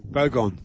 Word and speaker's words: Bogong: Bogong moths. – Bogong. – Bogong. – Bogong. – Bogong. – Gong. Gong, as in Wollongong.